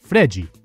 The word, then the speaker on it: Fred: Brazilians pronounce it Fredji.